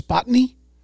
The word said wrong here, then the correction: spontaneity: Is that spontaneity? – Spontany? – Spontaneity. – No. – Is that it? – Yeah.